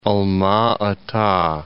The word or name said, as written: Alma-Ata